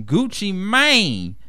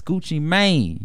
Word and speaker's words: Gucci Mane: I don't know why people still say Gucci Man. It's Gucci Mane. You got to add the E in there. – No. The E is silent. Just make it the long A sound.